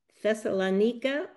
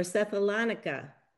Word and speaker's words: Thessalonica: Well, welcome to the newly established Church of Thessalonica or Thessalonica. Both pronunciations are correct depending on where you are in the world.